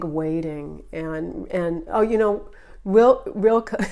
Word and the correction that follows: Rilke: am I pronouncing? No, don't Wilke. I'm always mispronouncing his name. Rilke.